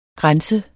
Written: grænse

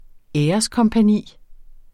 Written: æreskompagni

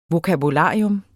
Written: vokabular

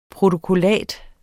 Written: protokollat

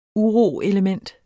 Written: uroelement

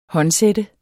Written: håndsætte

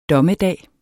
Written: DOMMEDAGEN

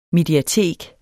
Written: mediatek

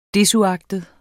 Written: desuagtet

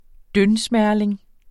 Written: dyndsmerling

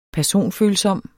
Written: personfølsom

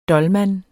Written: DOLMANEN

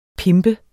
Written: pimpe

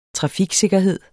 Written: trafiksikkerhed

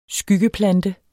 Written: Skyggeplante

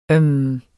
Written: øhm

